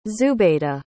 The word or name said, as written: zubeda